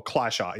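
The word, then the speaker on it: Klescher: Or Klesher is actually how it's supposed to be pronounced, apparently. – Oh, which really? – Weird to me. – Klesher? – Yeah, it's Klesher, as if it had an I after the E.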